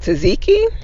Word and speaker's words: tzatziki: Is it tzatziki? – There you go. – Yeah. – She's good at that. – I love tzatziki sauce.